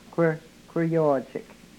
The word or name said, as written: kwrhkwryoochek